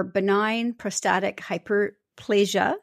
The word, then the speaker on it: benign prostatic hyperplasia: And BPH, or benign prostatic hyperplasia, if I said that correctly? – Correct, yes.